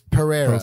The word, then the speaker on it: Pereira: Andreas Pereira. Am I saying that right? – Yeah.